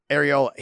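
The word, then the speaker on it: Arreola: And then Chris Oriola. How do you say his last name? – Ariola.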